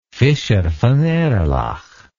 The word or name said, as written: fischer von erlach